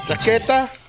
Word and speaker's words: chaqueta: How do you spell that again? – C-H-O-Q-U-E-T-A. – Chaqueta. – See, no, we call it chaqueta. – C-H-A. – Chaqueta. Oh, C-H-A. Okay. – C-H-A-Q-U-E-T-A. Chaqueta. – Yeah. Chaqueta, yeah. Chaqueta, jacket. – Jacket, okay. Well, so I was saying it wrong.